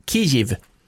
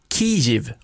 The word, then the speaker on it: Kyjiv: Nu ska man säga Kiev. – Det låter ju som en kiwi-frukt. – Ja, Kiev. – Ja, okay. – Och det stavas också K-Y-J-I-V. Och ska uttalas Kiev, om jag har förstått det rätt.